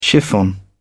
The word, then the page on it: chiffon: British English